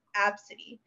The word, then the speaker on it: ABCDE: But then as the weeks went on, I was more like, hey, my name's Apsody. That's how you say it.